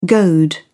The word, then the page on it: goad: British English